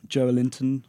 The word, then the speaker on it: Joelinton: I think in terms of the lesser clubs, I suppose, the smaller clubs, Joelinton, is that how I'm pronouncing it? – I believe so, yes.